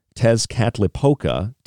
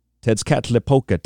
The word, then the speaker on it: Tezcatlipoca: But before chanting the name of the Aztec god, and if you'd like to spell it, I can spell it for you, Tezcatlipoca, T-E-Z-C-A-T-L-I-P-O-C-A,